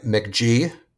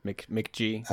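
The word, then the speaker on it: Mk.gee: He goes by McG, which is spelled MK period GEE all together. – McG.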